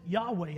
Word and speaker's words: Yahweh: There are no vowels in the word. Now, we pronounce it Yahweh.